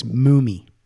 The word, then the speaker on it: Mumy: And it's Mumy. – Mumy? – Yeah. It's not Mumy. – Because you were saying mummy before, so I didn't know how to say it until he was on here, and he told me, he corrected me.